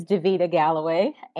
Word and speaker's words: Davita Galloway: So my name is Davita Galloway, and you did pronounce it.